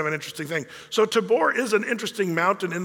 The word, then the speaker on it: Tavor: And in Israel, they pronounce it with a V, Tabor.